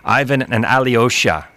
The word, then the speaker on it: Ivan and Alyosha: I just came on at noon, guys, and I get an act tonight that's playing with the Lone Bellow, Ivan and Alyosha. I said that right, right? – Yeah.